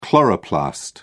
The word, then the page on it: chloroplast: British English